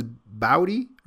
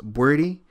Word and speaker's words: Boadi: Now that's how it's pronounced, but the spelling is crazy. B-O-A D-I. So it looks like it says Bowdy or Bowdy, but it's pronounced Bordy.